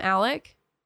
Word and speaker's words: Alex: Also, his name is Alexander, but they call him Alec. But it's spelled Alex. It's annoying.